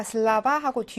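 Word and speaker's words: 슬라바 (слова): 복수를 만들려면 근데 복수가 됐는데 강세가 슬로버였다가 슬라바하고 뒤로 이동을 한답니다. 강세가 슬라바 됐으니까 여기는 강세가 없어서 아 발음이 나겠죠?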